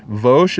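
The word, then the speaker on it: Vosh (בוש): Vosh is how you say ashamed or bashful in Hebrew.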